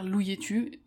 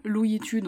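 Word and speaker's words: Louyetu: Je sais pas si tu te souviens de l'affaire Louietu, est-ce que même tu en as entendu parler, j'en avais parlé dans quelques-uns de mes épisodes, mais maintenant, si tu tapes Louietu,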